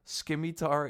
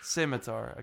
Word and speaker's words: scimitar: Because people were, like, talking about it, that my pronunciation of scimitar is not correct. So how would you say it? – Scimitar. – Scimitar, okay. Yeah, well, yeah. – The C is silent, I believe. So it's okay. Scimitar.